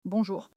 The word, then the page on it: bonjour: French